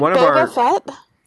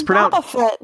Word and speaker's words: Boba Fett: Isn't it Boba Fett? Why would you say Boba Fett? – Because it's pronounced both ways in the movie, so I mix it up personally.